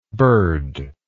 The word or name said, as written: birdless